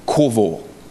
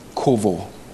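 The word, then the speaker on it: korvor (קָוָה): And it's actually a Hebrew word that looks like it says quaver. And the word quaver is actually pronounced korvor, korvor.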